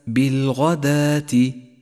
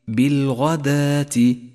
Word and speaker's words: بالغداة: المثال التالي اقرأ كلمة بالغداة، بالغداة، ولاحظ الواو الرمادية التي تكتب ولا تلفظ، وهي واو خاصة بالرسم القرآني، بالغداة تكتب ولا تلفظ ولذلك هي رمادية.